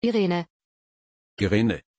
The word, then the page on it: irene: German